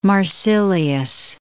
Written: Marsilius

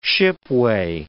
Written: shipway

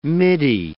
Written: midi

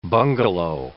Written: bungalow

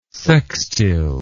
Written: sextile

